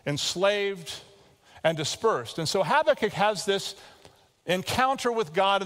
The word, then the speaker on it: Habakkuk: You can also pronounce it Habakkuk, and actually both pronunciations are correct, believe it or not. But I'm going to go with Habakkuk because it just flows a little better.